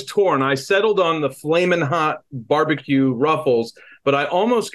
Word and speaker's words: Flamin' Hot: but I caught the hard G there, the Flaming Hot, but it's Flamin Hot.